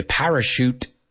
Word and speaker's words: parachute: Some of them, you're the parachute. Vamos a pronunciar esto. Parachute, porque es TH, pero se pronuncia como SH.